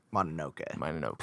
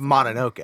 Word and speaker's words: Mononoke: Mononoke. Please get this right. – Oh, excuse me. – It's not that fucking hard. Excuse it's Mononoke.